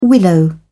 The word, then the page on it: willow: British English